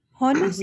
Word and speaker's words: honest: Ustaza, H is silent. You can start with O. Honest. Yeah, honest, okay. – Yeah, it's a common mistake we Pakistanis make.